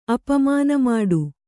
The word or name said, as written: ಅಪಮಾನಮಾಡು